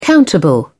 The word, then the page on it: countable: British English